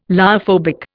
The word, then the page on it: lyophobic: Indian